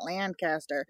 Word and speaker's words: Lancaster: But if you're in Lancaster County, Pennsylvania, and yes, it's Lancaster, not Lancaster. – Wait, how do you say it? – Lancaster. – Lancaster.